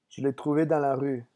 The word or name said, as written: je l'ai